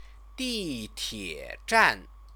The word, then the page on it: ditiezhan: chinese